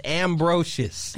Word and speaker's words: Ambrosius: That was Nipsey Hussle featuring Marsha Ambrosius. I hope I said it right. – No, you didn't. It's Ambrosius.